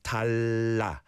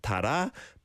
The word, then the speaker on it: 달라: But when you've got a liel patchim and another liel, then it's talla. So you got the sound at the bottom of the first syllable. Talla, talla. Not tara, but talla.